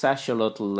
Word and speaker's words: axolotl: Well, in Spanish, it's axolotl, actually.